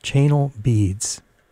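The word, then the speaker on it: Chanel Beads: this is really the way you're supposed to pronounce the name. Channel Beads. Trust me, that's what the notes say.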